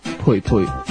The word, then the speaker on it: 蓓蓓: 家里人称呼她为贝贝。贝贝的广东读音是“ 佩佩”，用广东话叫她也没有什么不顺耳的。